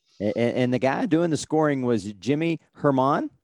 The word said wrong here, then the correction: German: Do I have that right? – German. – Okay. – It's just German.